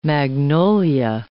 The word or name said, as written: magnolia